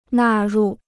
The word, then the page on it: 纳入: nà rù